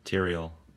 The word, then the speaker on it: Tyrael: How do you pronounce that? – Tyrael. – Tyrael.